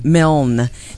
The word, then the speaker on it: Milne: Milne. That's the right way to say it.